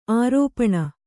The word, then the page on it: ಆರೋಪಣ: kannada